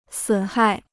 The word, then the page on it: 损害: sǔn hài